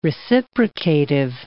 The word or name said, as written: reciprocative